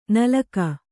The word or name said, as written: ನಲಕ